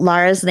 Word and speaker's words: Lara: So Andy pronounced Lara's name like 10 different ways. And finally, at the end of the episode, she explained, you say Lara with a smile.